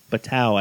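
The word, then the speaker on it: Batou: Bato? I think. Or Batau. Batau, I believe, is how you pronounce it.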